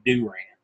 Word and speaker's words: Durant: It's Durant. And I was like, that's the most hick way of saying anything I've ever heard in my life. Is it Kevin Durant? Yes, it's not Kevin Durant.